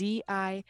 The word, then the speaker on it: Zivny: And then I do all of my advertising through Instagram, which is at Shelby Zivny, all one word. – How do you spell that? – Oh, my last name is Zivny. It's very Czech.